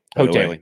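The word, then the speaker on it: Houghtaling: Hotailing. Yeah. That's how you pronounce it. – I just call him Coach Haas. Because Jason Hotailing. – Because it's not spelled like Hotailing.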